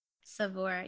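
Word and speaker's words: Saveur: This one just happened to be from, I never know how to say this. Is it Savour magazine? – Savour. Yeah.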